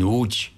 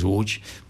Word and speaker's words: Łódź: Entonces cuando hice el examen en UCH, o como se dice internacionalmente, Łódź, ¿no? Porque así se escribe, pero en polaco es UCH, una ciudad grande, y hice el examen de acceso.